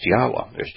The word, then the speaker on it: Yahweh: And what you find is that a Yod, He, Waw, He, it'd be pronounced Yahweh.